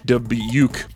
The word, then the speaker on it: Dubuque: I thought I knew how to say Dubuque, but it's dub like dubstep, uke like youth with a K. Anyway, Dubuque.